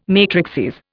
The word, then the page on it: matrices: Indian